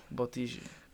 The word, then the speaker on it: Bottigi: If I don't use the accent, it comes out wrong. Bottigi.